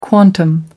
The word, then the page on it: quantum: American English